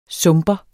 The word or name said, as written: sumper